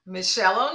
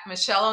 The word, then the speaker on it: Mischelle: Meet MEET dot Michelle O'Neill, spell my name correctly, dot com.